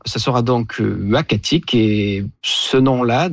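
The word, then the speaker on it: Uhackatic: Et donc, on s'est mis d'accord sur Wakatik, qui désigne un endroit propice à la chasse du caribou.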